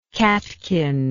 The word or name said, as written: catkinate